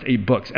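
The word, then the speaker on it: AbeBooks: And I cannot convince this person that it's pronounced Abe Books.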